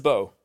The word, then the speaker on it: Bo: name is Bo, just B O.